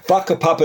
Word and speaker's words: whakapapa: Whakapapa is spelled with a WH, but in Maori, WH is the letter F. Sounds like an F.